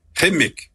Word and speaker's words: Qimmik: Euh, on, en fait, on prononce le cas comme en arabe, c'est Kimik, mais c'est correct de dire Kimik aussi.